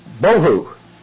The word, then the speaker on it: bohu: Well, this word looks like this in the Hebrew, similar in appearance, and it should be actually be pronounced with a soft B sound. We might say V, but let me just put B-O-H-U, Bohu.